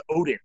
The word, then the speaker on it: Odin: One quick thing, it's actually pronounced Odin. – Oh, Odin. – Okay, yeah. – All right. Like God.